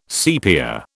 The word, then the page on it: sepia: British English